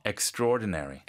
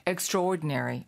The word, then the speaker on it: extraordinary: 스펠링은 extra하고 ordinary인데 네네. 발음이 좀 특이해요. Extraordinary. – Extraordinary. 맞다. – 원래 그 천천히 발음은 extraordinary. – 네. – But normal speed, extraordinary.